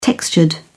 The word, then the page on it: textured: British English